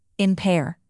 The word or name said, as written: impair